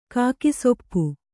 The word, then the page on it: ಕಾಗೆಸೊಪ್ಪು: kannada